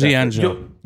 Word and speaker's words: Yoji Anjo: And in October, he had his debut match in UWF wrestling under the name Wayne Shamrock and defeating Yojo Anjo. Am I saying that correctly? – That's the best. Yoji Anjo. – Yoji Anjo? I'm terrible. Sorry.